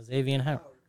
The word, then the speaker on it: Xavien Howard: How do you pronounce his name? – Who? – Xavien Howard? – Yeah, Xavien Howard.